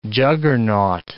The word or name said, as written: juggernaut